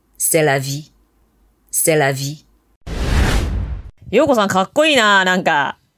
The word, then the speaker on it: C'est la vie: But no, you say the correct pronunciation, but we aren't talking about French here. We are talking about 普通せ英語。So my pronunciation is correct. C'est la vie.